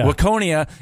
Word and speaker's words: Waconia: Waconia is how you pronounce it, to see a Suburbs show.